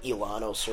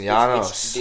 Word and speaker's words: Llanos: You pronounce it like Ilanos or whatever, which means like the plains of Colombia. – Oh, okay. It's pronounced Llanos. – The IL or whatever. – Yeah, there's no J. I wasn't seeing a J or a Y or anything, so...